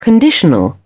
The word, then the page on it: conditional: British English